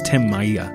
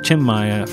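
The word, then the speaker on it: Tim Maia: We had a classic from Chim Maya, who for years I used to pronounce as Tim Maya, but now Chim Maya,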